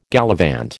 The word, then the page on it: gallivant: American English